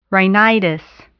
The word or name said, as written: rhinitis